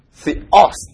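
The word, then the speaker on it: theos (θεός): 然后这里呢是母音跟子音，os theos 啊，重音在这里，这里没有重音，这个抑扬音色读一样 theos theos 啊，一样的重量啊 theos theos。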